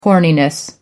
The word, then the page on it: horniness: American English